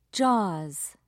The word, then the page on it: jaws: British English